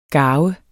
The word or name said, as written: garve